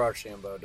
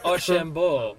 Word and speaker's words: Archambault: How do you say your last name? – Archambault. – Archambault, okay. – Yeah, or Archambault, depending on what part of Canada you're from. – Archambault. – Yes, Archambault or Archambault.